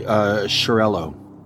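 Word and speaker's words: Xarel·lo: Zarello? – Mm-hmm. Yeah. – Xarel-LO. – It's probably Shirello. – Shirello. – Yeah, probably X is a sh sound.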